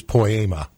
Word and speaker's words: poema: But no matter how she pronounces it, it is a word from God in the form of a P-O-E-M, poem, poem, whatever it is. – She's just pronouncing it the Greek way, which is poema.